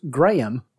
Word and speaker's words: Graham: So who is Graham? Because remember, it's two syllables. – Graham. – Two and a half syllables.